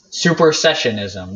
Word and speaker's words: supersessionism: Suppressionism? supersessionism. That's it.